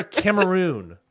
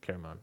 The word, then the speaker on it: Cameroon: Survivor Cameroon. – Okay, so it was a while ago. – Or Cameroon? Caramon, whatever it is. – Oh, you said it right the first way.